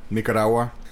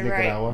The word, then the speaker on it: Nicaragua: I'm from a small country in Central America, Nicaragua. – See, he says it Nicaragua. Right. – Very small. – Us Mexicans say it wrong.